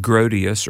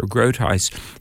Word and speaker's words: Grotius: By the way, one of the founders' incredible influences was sometimes pronounced Grotius or Grotius.